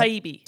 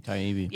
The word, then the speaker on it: Taibbi: Matt Taibbi. – Taibbi.